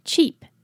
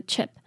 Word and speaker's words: cheap: 再来 cheap, cheap, 这里的 cheap 呢，记得 EA 是 E 的音，是长音哦，不是短的 E, 因为如果是短的话就会变成 chip, chip, 那就是 CHIP, 就是完全不同的意思了， OK, 这里是 cheap, cheap.